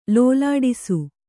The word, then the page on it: ಲೋಲಾಡಿಸು: Kannada